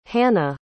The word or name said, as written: hanne